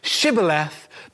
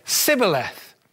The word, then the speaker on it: Shibboleth: This incredible story tells us that the foreign people could not pronounce Shibboleth, but rather they would say Sibboleth.